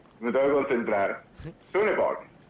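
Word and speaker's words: Sønderborg: Y claro, el Sonderborg es la O con el palito así atravesado. – ¿Sí? – Y esa O llevo 21 años y todavía no la puedo pronunciar. Porque es una cosa entre O y U.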